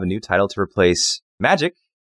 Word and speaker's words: Magyk: called Magic, but it's spelled M A G Y K.